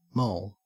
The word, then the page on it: moll: Australian English